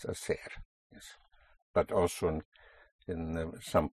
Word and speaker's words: hacer: To do or to make is hacer. Spelled H-A-C-E-R. Again, the H is not pronounced, so you have hacer, to do.